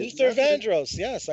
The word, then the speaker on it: Luther Vandross: Luther Vandross, I believe. I can't pronounce his name. – You got it. Luther Vandross. Yes, I know Luther Vandross.